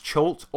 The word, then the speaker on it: Cha'alt: So what we'll do, we'll get into a bit of Ch'alt now, which he says is pronounced Ch'alt or however you want to pronounce it. That's what he said. – I like that. I'm going to pronounce it Venger Satanis' Magical World of Fun. – No. – Is that right? No. Okay. – No, stick with Ch'alt.